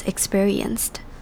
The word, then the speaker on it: experienced: And ultimately, 95 students experienced. 好，我们来看 experience 这个字。首先要注意的地方就是第一个字首的 e 读成 e 的声音 experienced。这个字第二个要注意的重点是，这边的 p 在这个字之中听起来会像 b，而不是 p 的声音，听起来会比较像字母 b 的发音 experienced。